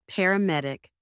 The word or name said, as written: paramedic